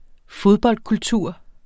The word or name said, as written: fodboldkultur